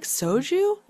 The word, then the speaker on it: soju: And the other part to this is actually, even though I haven't really found a sake I'm into, I like soju.